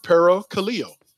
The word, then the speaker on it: parakaleo: But in the Greek, encourage means parakaleo. And parakaleo is spelled P-A-R-A-K-A-L-E-O.